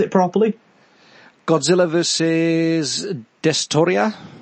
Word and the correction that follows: Destoroyah: It's a Destoroyah.